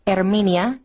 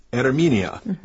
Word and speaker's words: Herminia: But in Spanish, when you see an H at the beginning of a word, it is silent. So it would be Herminia, not okay. – Herminia. – Herminia, not Herminia.